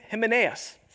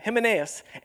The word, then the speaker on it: Hymenaeus: Among them are Hem- is Hymenaeus, said it right, Hymenaeus and Philetus, who have swerved from the truth, saying that the resurrection has already happened.